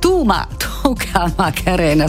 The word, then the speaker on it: Tucamacarena: Lei era Baby K con la sua Tucamarena, che è Tucamacarena, scusate, io ogni volta che pronuncio questo titolo mi sbaglio perché è quasi uno scioglilingua. Simpaticissimo comunque questo pezzo, davvero molto molto estivo.